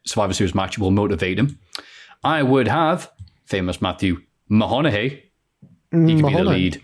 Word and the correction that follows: McConaughey: He means McConaughey.